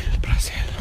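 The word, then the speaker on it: Brasil: I just like a Z, not pronounced as a Z, because obviously it's an S for them. – Yeah. – Brazil.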